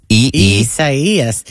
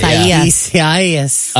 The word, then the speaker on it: Isaias: Isaias. – S-A-I-E-E-U-S. – Think acai berry. Yeah. – It sounds like that. – Isaias.